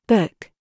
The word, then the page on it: COOK: British English